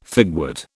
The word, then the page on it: figwort: British English